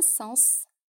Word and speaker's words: sens: Agora eu vou te dar uma pequena listinha de palavras, as mais comuns, em que você deve pronunciar o S final. Vamos lá? Un bus, um ônibus. Un sens, um sentido. Un fils, um filho. Mars, março. Un ours, um urso.